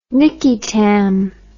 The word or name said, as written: nicky tam